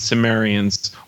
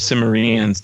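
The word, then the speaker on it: Cimmerians: And the frontiers of the world where the fog-bound Sumerians, or Sumerians, depending on your pronunciation, lives in the city of perpetual mist.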